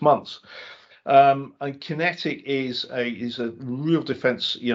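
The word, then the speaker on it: QinetiQ: Kinetic, and this is spelt, just so if you're looking it up as an investor, it's Q-I-N-E-T-I-Q, and ticker is QQ, 2.2 billion market cap. And the shares in Kinetic, and this is one that Andrew and I were looking at defense a couple of months ago.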